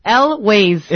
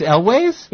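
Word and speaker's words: Elwes: Elways. – It's Elways? – Yeah.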